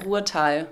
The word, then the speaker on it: Ruhrtal: Habe ich das richtig ausgesprochen? Ruhrtal. Ruhrtal. Ruhrtal-Werkstätten in Düren, okay.